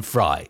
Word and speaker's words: Frey: There's also an appearance from Glenn Frey, or is it Glenn Fry? It's spelled one way, pronounced the other.